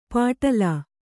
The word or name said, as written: ಪಾಟಲಾ